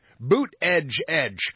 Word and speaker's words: Buttigieg: Apparently, the correct pronunciation for Pete Buttigieg's name is, in fact, Pete Buttigieg.